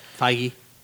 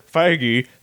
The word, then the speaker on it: Feige: I thought it was Feige. – Feige. – Is it Feige? – It's pronounced Feige. – Really? – It's spelled Feige, but it's pronounced Feige.